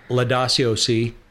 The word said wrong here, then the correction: Laudato: I don't think that's how you pronounce that. – What is it? – Laudato.